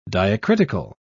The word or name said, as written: diacritical